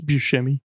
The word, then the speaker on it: Buscemi: Never knew how to say the name. Buscemi. Don't at me. – It's Buscemi.